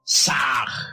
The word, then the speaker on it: Sah: Ham se escribe con las letras H-A-M. Sah se escribe con las letras S-A-H. La H suena siempre como J.